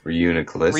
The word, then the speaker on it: Reuniclus: Reuniclus.